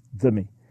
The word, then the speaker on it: dhimmi: It is D-H, the way it's spelled in English and pronounced like the, dhimmi.